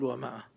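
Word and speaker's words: مائة: ومئة لا تقول ومئة، قول ومئة. – أحسن. – هي ومئة، الألف اللي حطوها هذه الزيادة ما حد ينطقها، بعضهم يقرأ 500، 500 الألف هذه زادت، زادوها في الكتابة حتى يفرقون بين المئة وبين فئة أو كلمة أخرى، هذا حرف يكتب ولا ينطق.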